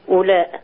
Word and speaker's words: أولاء: الواو دي لما يترسم عليها الدايرة الصغيرة دي معناها أنها لا تنطق أولاء.